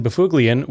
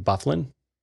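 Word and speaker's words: Byfuglien: Dustin Bufuglian, which is the correct pronunciation of his last name, so get out of the comments. So it's like a Norwegian name that's pronounced like he's a Canadian guy, but his like parent or grandparent was Norwegian. And so it's pronounced Bufuglian, and like announcers couldn't be bothered. So they just pronounced it Bufflin.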